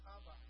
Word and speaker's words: Chava: You want to learn how to say her name in Hebrew? It's Chava. C-H-A-V-A.